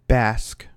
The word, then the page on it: bask: American English